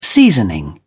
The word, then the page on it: seasoning: British English